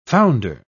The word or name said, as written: founder